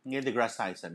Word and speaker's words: Neil deGrasse Tyson: How do you say his name? Is it Neil deGrasse Tyson? – Neil deGrasse Tyson, yeah.